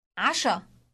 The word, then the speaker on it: عشاء: عشاء عشاء Note the initial sound pronounced in the throat.